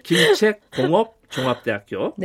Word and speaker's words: 김책공업종합대학교: 이 이제 에, 예, 김정은 국무위원장 시대에 이제 이것도 어 2013년인가 14년도에 완공된 거로 알고 있는데 그 거리에 바로 김책공업대학 종업 공업 공업 종합대학 발음이 어려워요. 김책공업 종합대학교.